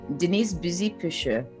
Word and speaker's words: Denise Buzy-Pucheu: Denise Buzy-Pucheu.